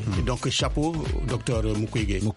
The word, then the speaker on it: Mukwege: Pour rencontrer d'abord beaucoup d'artistes, mais aussi voir ou interviewer monsieur le docteur Mukwege.